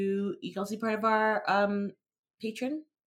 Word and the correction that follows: Patreon: I always say that wrong. Patreon.